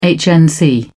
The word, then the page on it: hnc: British English